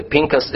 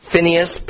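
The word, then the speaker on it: Pinchas (פינחס): The Pinchas is just more the Hebrew pronunciation. Phinehas,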